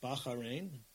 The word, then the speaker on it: Bahrain: So, for example, my last two trips were to Bahrain, although they pronounce it Bahrain, out there.